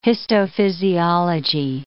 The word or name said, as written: histophysiology